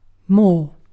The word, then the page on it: more: British English